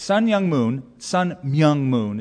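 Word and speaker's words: Sun Myung Moon: Sun Myung-moon is the pronunciation.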